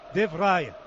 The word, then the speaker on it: De Vrij: De Vrij es De Vrij, entonces. – Sí, me lo ha dicho Oliveros, que así lo pronuncian en la Fundación Cruyff, así que yo lo mantengo. – Lo hemos preguntado por audio, por WhatsApp, y nos dicen De Vrij.